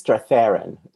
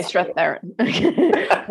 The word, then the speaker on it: Strathairn: is it David Strathairn? I never know how to pronounce it. – I think it's Strathairn. – Strathairn.